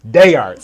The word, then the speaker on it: D'Art: I've actually heard you say Day Art. So I'm like, you know what? – I don't want to. Yeah, you actually said it right, so I was surprised. I was like, oh, wow, that's pretty cool. You're like probably the first person to say it correct.